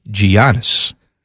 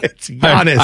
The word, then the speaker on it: Giannis: My goal for this year is to get you to say his name with a Y instead of a G. – No, I'm saying Giannis.